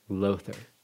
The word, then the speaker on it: Lother: Like loafer, Lother. – Yep. That's a yes.